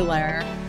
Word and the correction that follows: Lahr: Did I pronounce that right? – Lar. It's Billy Lahr. – Lar.